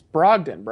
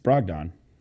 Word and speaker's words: Brogdon: You can't even say his name correctly. It's Brogdon, bro. – It's Brogdon. – Not Don.